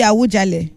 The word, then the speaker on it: Awujale: Awujale, I'll be Awujale. – Awujale. – Okay, that's the pronunciation.